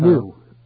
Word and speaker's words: gnu: Gnu is how you pronounce it. N-O-O. It's spelled G-N-U, but it's pronounced N-O-O.